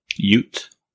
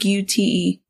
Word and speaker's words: Ute: Don't kill me if I butcher this, but I want to say it's UT uh, Native Reservation. The U- UT. – Ute. – UT. U like U T E. Ute. – No, Ute. Oh, Ute.